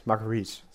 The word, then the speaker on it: Marguerite: Marguerite, so wird sie ausgesprochen, glaube ich.